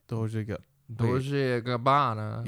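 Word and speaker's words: Dolce & Gabbana: Dolce Gabbana.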